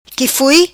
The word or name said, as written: qui foi